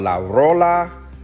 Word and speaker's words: La Rola: It's called La Rola Urban Colombian Food. Is that right? Did I say it right, love? Did I say it right? Yes.